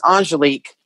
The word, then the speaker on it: Angelique: My name is actually, and it's okay. It's pronounced Angelique, but I knew I hated if I ever heard him call me that because I'm like, okay, I'm in trouble.